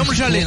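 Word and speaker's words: Domžale: Alors voilà, alors ça, c'est l'hymne de l'équipe slovène de Domzalé, ou Domzal. Je sais pas comment on prononce d'ailleurs, peut-être que tu nous verras. – Domzalé, normalement.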